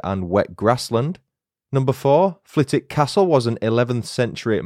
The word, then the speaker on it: Flitwick: Number one, Flitwick has a silent W in its name. Whilst writing the script for this episode, I was convinced that the town's name was pronounced Flitwick, as in Professor Flitwick, but alas, I was wrong. It's pronounced Flitwick, despite being spelled F-L-I-T-W-I-C-K.